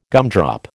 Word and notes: gumdrop: American English

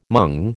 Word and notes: mung: American English

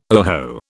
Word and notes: oho: British English

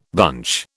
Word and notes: bunch: British English

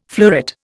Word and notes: fleuret: Indian

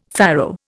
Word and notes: farrow: Indian

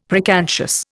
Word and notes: preconscious: Indian

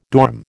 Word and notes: dorm: American English

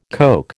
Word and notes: Coke: American English

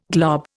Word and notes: glob: Indian

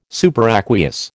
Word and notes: superaqueous: American English

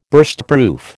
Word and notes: Burstproof: American English

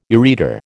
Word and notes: ureter: American English

